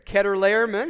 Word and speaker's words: Chedorlaomer: And so, the next verse says, in the 14th year, Chedorlaomer, and that is how you say it. I listened to an app like 20 times to get that right.